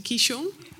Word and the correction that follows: Qigong: Qigong. – Qigong.